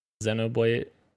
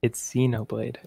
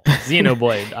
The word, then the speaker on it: Xenoblade: Xenoblade. Did I say it wrong? – Oh, I love this guy. I love this guy. Keep going. – Did I say it wrong? – It's Xenoblade. – Oh, Xenoblade.